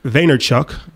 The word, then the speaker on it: Vaynerchuk: Yeah, so, you know, Gary Vaynerchuk.